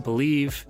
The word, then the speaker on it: Bleav: The Mosaic Life Podcast has officially joined the Believe Podcast Network, spelled B-L-E-A-V, pronounced Believe, and I seriously could not be more thrilled to grow with them well into the future.